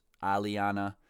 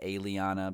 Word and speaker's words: Alianna: A-L-I-A-N-N-A. Which I feel like normally you would want to pronounce Aliana, but I believe she pronounces it Aliana because her little, like, click type shit is the aliens, if I'm not mistaken.